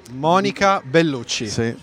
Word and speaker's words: Monica Bellucci: Yeah, you have to pronounce it right. Monica Bellucci.